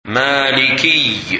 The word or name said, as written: Maliki